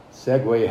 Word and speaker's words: segue: Segue happens to be a Portuguese word, S-E-G-U-E, and in Portuguese it's pronounced segue.